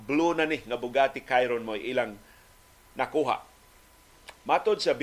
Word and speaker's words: Chiron: Ang Bugatti Chiron, mao na siyang Americanized nga pronunciation. Ang kung gusto juga mag-French o gusto ka mag-Italian, ang ilang isulti, Bugatti, Bugatti uh, Chiron ang, ang argon sa Spain imo mag ipakara ta imo tutulan. so dili dili ka mong yung Chiron sa sa French. Kung gusto gyud ka mag French, Chiron.